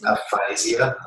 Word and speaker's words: aphasia: Aphasia.